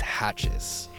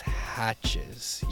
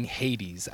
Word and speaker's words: Hatjes: Okay, so my last name is Hatches. – Hatches. – Yes, it is spelled funny. H-A-T-J-E-S, Hatches. Yes, Shawn Hatches. – That's how I was told it was pronounced.